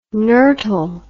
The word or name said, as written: nurtle